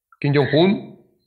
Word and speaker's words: Kim Jong-un: Kim Jong-un. – Kim Jong-un.